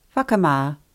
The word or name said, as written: whakama